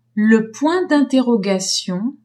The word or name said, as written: point d'interrogation